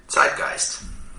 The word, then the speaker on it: Zeitgeist: Zeitgeist. That is the correct German pronunciation. Zeitgeist. The way I usually hear people say it here in the U.S. is Zeitgeist. But try and go for a little bit harder Z, like a T-S.